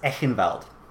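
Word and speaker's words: Eichenwald: I believe it would be pronounced Echenwald.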